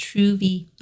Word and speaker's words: trouvaille: So it's spelled T-R-O-U-V-A-I-L-L-E. So it looks like trouvaille, but pronounced trouvé.